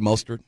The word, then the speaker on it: Mostert: Well, you got to give Mostert his – am I saying it right, Mostert? – Yeah.